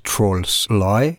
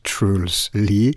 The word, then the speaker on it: Truls Lie: That's how his name is pronounced, not Trolls Lie, but Truls Lie.